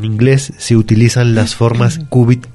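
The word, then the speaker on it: cúbit: La palabra es qubit y se escribe C-U-B-I-T, con acento qubit en la U, ajá.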